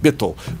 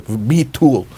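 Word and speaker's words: Bitul (ביטול): She's pronounced, you know, I'm saying with Ashkenazic accent, Bitul. But yeah, Bitul, you know, the vowel, vocalization, the vowel is a chirik and a shurik, Bitul.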